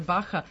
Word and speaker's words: Barra: Inače, Baha je brazilsko-portugalski izgovor. Barra se piše sa dva R, ali oni to kažu H, eto.